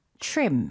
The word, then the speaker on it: trim: Trim. – Trim. – 이거는 이름절로 발음해 주시고요. 이거는 원어민들이 TR보다는 trim 이거보다 CHR 느낌으로 뭉쳐서 이렇게 발음하거든요. – 그러니까 Christmas tree라고 하는 것처럼. – Yeah.